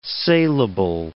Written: salable

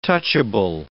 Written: touchable

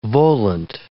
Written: volant

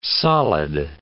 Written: solid

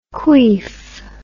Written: queef

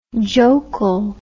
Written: jokul

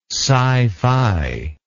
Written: scifi

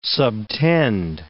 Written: subtend

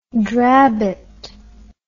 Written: drabbet

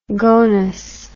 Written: gonus

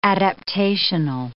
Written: adaptational

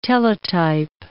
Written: Teletype

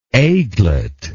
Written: aiglet

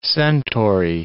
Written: centaury

